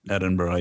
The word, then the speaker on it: Edinburgh: So you did uh, Westmont for undergrad, graduate work in how do you say it? Edinburgh, Edinburgh, Edinburgh, Edinburgh. Edinburgh yeah, yeah.